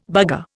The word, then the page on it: bugger: Indian